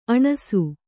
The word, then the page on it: ಅಣಸು: aṇasu